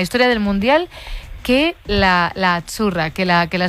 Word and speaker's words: azzurra: Azzurra. – Azzurra. Mira, ¿ves? – Azzurra. Es azzurra. Que... Sí, como porque pizza. La zeta no es la zeta como es decimos en catalán tan sonora, no.